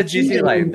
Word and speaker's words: Ghislaine: How do you say its name? – We call her Ghislaine, don't we? – Ghislaine is a good name.